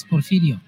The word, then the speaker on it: Porfirio: And in Spanish, it's Porfirio. – Porfirio.